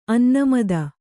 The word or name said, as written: ಅನ್ನಮದ